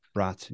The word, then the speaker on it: Braatz: Braatz. Yeah. Like pronounced like multiple German sausages.